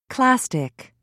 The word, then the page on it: clastic: British English